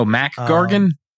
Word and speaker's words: Mac Gargan: You said Mark Gargan. He is. – Oh, Mac Gargan.